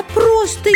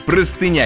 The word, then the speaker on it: простыня: Я вообще думала, что правильно говорить не простыня, а простынь. – И снова вы оба получаете по штрафному очку. Правильный вариант – простыня единственное число, простыни множественно.